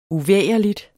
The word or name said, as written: uvægerligt